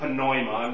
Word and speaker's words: pneuma: Pneuma, pneuma, they actually pronounce the P in Greek. It's not silent, so they would pronounce it pneuma, not pneumonia.